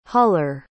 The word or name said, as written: huller